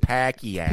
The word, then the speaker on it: Pacquiao: Pacquiao. – I'm fluent in Filipino. – You are. – Waisuki. – It's all he. – My people. – Waisuki. But this is Pacquiao. Yeah, it's pronounced Pacquiao.